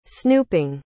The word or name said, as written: snooping